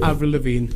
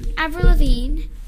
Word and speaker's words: Avril Lavigne: Avril Lavigne. – Avril Lavigne.